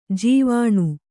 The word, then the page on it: ಜೀವಾಣು: jīvāṇu